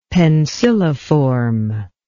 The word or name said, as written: penciliform